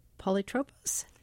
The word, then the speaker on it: polytropos: In a New York Times article about your work in the Odyssey, you talk about the first adjective to describe Odysseus, polytropos. Am I pronouncing that correctly? – Yes, that's fine. Yes.